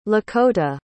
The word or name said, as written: Lakota